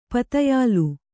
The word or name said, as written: ಪದಯಿಲ